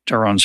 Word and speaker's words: Daron: I've mispronounced Daron's first name. So those of you who hear those and are wondering which one's right, uh, it's Daron.